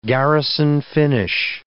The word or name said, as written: Garrison finish